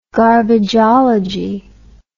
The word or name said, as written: garbageology